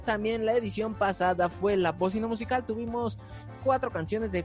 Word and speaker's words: La Bócina: Nos encuentras en redes sociales como La Bocina, acento en la O en todas las plataformas. Y en Instagram como La Bocina Crew, C-R-E-W.